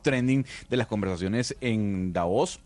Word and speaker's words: Davos: Fernando Peset dice que se dice Davos porque es sin tilde en español, por lo tanto es clave.